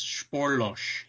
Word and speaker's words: Spoorloos: Yeah, which had me pronouncing it Spoorloos for a long time, but I guess it's Spoorloos. – Oh, Spoorloos, I blew it. – Yeah, I looked it up on Google one day. I'm like, I have to know how to pronounce this damn movie. And so it's Spoorloos. There are a lot of odd pronunciations around this movie, so we'll probably be butchering foreign languages for the next hour.